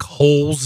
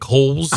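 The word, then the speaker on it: Holz: What can he tell us about Nick Holes? Is that how you say it, Nick Holes? – I guess so. We can ask Tony. Maybe he'll know. But yeah, Holes with a Z. H-O-L-Z.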